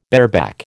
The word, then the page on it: bareback: American English